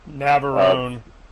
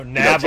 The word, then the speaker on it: Navarone: And you know, it was like the guns of Avaro, right? Is that how you pronounce it? – Navarone. – Avarone, that's it. – No, Navarone.